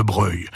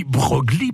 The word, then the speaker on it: Broglie: Et eh oui, parce que si on dit Broglie pour la commune, on prononce Breuil pour le nom de famille, car les habitants n'ont plus s'accoutumer à la prononciation italienne en usage dans le monde politique et aristocratique.